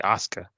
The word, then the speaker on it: Asuka: You say Asuka, but you're kind of skipping over the U.